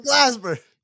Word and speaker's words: Glasper: If you said it right the first time, it would have been really funny. – I didn't say it right the first time? – You said Glasby.